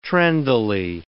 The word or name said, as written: trendily